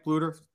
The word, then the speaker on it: Bluder: Lisa Bluder, I think that's how you pronounce her name, right? Bluder, the Iowa coach. – Yes.